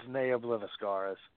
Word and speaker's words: Neo Obliviscaris: It's Neo Obliviscaris.